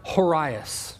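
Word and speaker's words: horaios (ὡραῖος): You know, that word beautiful in the Greek, it's the Greek word horaios. You're supposed to roll your R there, but I can't do that, and I would just fumble.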